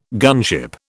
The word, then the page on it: gunship: British English